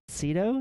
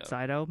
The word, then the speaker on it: Sydow: why don't you cast Max von Sydow? Is that how you pronounce it?" – I think it's Sydow. – Sydow, Max von Sydow, because he vaguely looks like him, and he does look a lot like him.